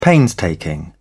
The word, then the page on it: painstaking: British English